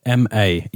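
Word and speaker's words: Ma (間): And through meditation practices, exactly, yeah, through running, I wasn't familiar with this concept of is it Ma? Is that how you say it? – M-A, yeah, Ma.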